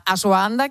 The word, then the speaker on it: ashwagandha: Del kéfir, de la creatina y de la ashwagandha, que no sé si lo pronuncio bien. – Sí, está bien.